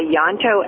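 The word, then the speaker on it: Ianto: We wrote down two books by Yanto Evans. I'm going to spell that for anybody who wants to look it up and isn't familiar. It's I-A-N-T-O, Yanto Evans.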